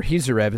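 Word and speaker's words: Khizriev: Well, moving on to the 3 fights that did happen, Timur Kizarev, or Kizarev is how they pronounced it, and Daniel Vichel.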